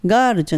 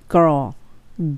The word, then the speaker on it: girl: ここのところの発音のポイントはガールじゃなくてゴロー、 l ロですね。